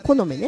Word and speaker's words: 木の芽: ですね、陰暦の2月、別名はこの目月といって、この目、木の目ですね、木の芽の、はい、木の芽の月と書いてこの芽月っていうそうです。まあ、この芽というのは春になって芽吹く木々の芽のことですね。うちのね、山椒芋ももう芽がついてますね。木の芽が、この芽ね、この芽が出る季節をこの芽時とも言います。